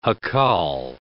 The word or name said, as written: jacal